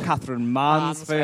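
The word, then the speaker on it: Katherine Mansfield: Catherine Mansfield.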